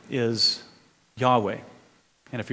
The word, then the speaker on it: Yahweh: Thanks to the German scholars, we have the J in the Hebrew language, which doesn't really exist. And so they've pronounced Yahweh as Jehovah, okay?